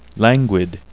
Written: languid

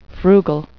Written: frugal

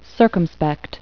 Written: circumspect

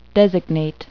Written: designate

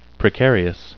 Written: precarious

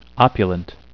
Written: opulent

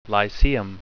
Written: lyceum

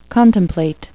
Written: contemplate

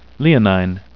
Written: leonine

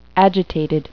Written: agitated

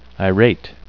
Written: irate

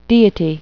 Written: deity

